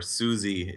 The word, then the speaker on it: Siouxsie: Suji is spelled Siouxsie. – Yes, like the Sioux Native Americans.